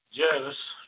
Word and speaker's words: Jervis: Is it Jervis Bay or Jarvis Bay for you? – Jervis. – It's Jervis.